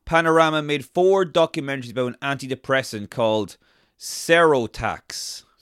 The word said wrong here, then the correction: Seroxat: Yeah, the secrets of, Seroxat. – Oh, right, I was way off, Seroxat.